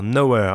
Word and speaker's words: Knower: Est-ce que tu peux nous les présenter Oui, alors Nowhere. Alors je dis tout de suite pour ceux qui, qui veulent, ça s'écrit K-N-O-W-E-R, comme un peu connaisseur en, anglais.